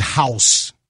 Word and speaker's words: Haus: There is a baked cheese house, H-A-U-S, pronounced house.